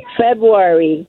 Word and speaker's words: February: February. Silent R. – Silent R. Okay.